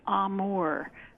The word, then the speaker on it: Amur: And they pronounce it Amur, like A-H-M-O-O-R.